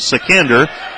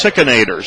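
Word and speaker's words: Sichender: Now, the spelling I have for Sickenator's last name is S-I-C-H-E-N-D-E-R. I asked if it was Sickender, and they said, no, it's Sickenator. So we'll go with the local pronunciation.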